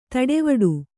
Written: ತಟವಟ

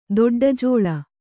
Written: ದೊಡ್ಡಜೋಳ

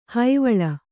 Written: ಹೈವಳ

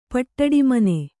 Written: ಪಟ್ಟಡಿಮನೆ

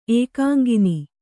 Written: ಏಕಾಂಗಿನಿ

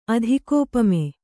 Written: ಅಧಿಕೋಪಮೆ

